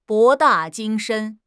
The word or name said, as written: 博大精深